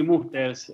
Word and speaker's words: Musters: Puntualmente el lago Masters o, Musters, no sé cómo se pronuncia. – Sí, Musters. Este, Musters.